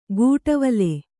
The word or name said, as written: ಕುಡವಲೆ